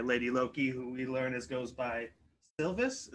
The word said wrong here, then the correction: Sylvie: Is that how they pronounce it? Sylvie.